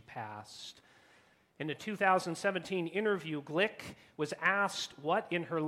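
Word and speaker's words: Glück: I understand her last name is pronounced Glick and not Gluck, though I've often pronounced it Gluck previously.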